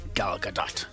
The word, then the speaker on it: Gadot: Yeah, we thought it was Gal Gadot for ages. – Everyone called her Gal Gadot. It's Gal Gadot. – It's more, you know, Gal Gadot.